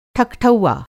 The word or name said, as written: ठकठौआ